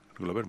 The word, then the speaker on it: Glover: siempre lo digo mal. Glover. – Glover, muy bien.